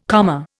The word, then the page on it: comma: Indian